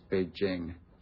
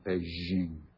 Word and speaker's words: Beijing: By the way, it's Beijing, not Beijing. That drives my wife crazy when they say it on the Olympics. Back in Beijing, my wife always says Beijing. It's a hard J there, is that right?